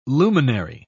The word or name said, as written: luminary